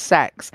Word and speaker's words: CEX: Because I've always called it CEX, but I have heard people say that it is actually supposed to be pronounced sex. And my sister the other day pronounced it kex. – Kex? Yeah. – That's great. – I believe it is pronounced sex.